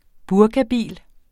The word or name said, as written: burkabil